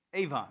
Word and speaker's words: avon: But it's the Hebrew word, it's pronounced avon. Avon. There's my southern accent ruining me from speaking good Hebrew. But it's spelled A-V-O-N.